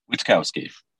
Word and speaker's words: Witkowski: I already forgot how to say your last name again, but we are here again with Brian Witkowski.